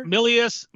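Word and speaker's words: Milius: I watched it, and John Millis, is that how you pronounce it? Millis? The director? – Millius.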